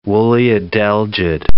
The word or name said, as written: woolly adelgid